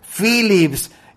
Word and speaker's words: Philips: En toda Europa, en Estados Unidos, en cualquier lugar, la PH se pronuncia como F, Phillips.